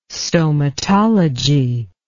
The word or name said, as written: stomatology